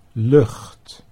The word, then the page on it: lucht: Dutch